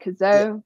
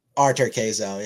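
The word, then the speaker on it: Cazaux: I think Auger-Aliassime is facing, I said his name wrong last week, Kazo. – Arthur Kazo, yeah.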